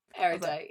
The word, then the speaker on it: Erudite: It's Erudite. Erudite was my worry. – Erudite, yeah.